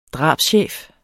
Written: drabschef

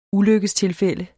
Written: ulykkestilfælde

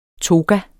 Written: toga

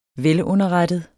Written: velunderrettet

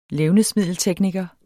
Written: levnedsmiddeltekniker

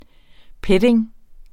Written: petting

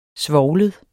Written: svovlet

